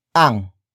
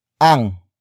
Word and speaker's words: ang: Up next we have ang. Ang. Ang. Ang is spelled as A N G.